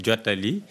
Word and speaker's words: Jutaali: parce que moi je ne prononce pas bien, probablement, Jutaali. – Oui, ça veut dire. – Bonjour. Vous le prononcez bien. Jutaali, c'est un mot Wolof sénégalais qui permet de dire fait passer.